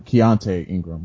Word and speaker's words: Keaontay Ingram: Cavante, uh, uh, I think I said that right. Keaontay Ingram, excuse me, the running back out of Texas.